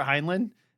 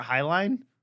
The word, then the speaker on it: Heinlein: I always thought it was Robert Heinlein. And they pronounce it as Robert Heinlein.